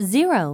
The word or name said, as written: 0